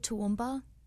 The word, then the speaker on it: Toowoomba: It's called Toowoomba.